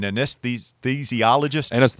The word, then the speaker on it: anesthesiologist: He wants to be, I'm going to mispronounce this, an anesthesiologist.